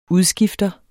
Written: udskifter

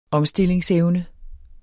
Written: omstillingsevne

